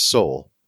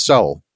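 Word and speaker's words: sál: And it's spelled S-A-L. And the A has a little accent mark over it. So it's pronounced soul. Soul.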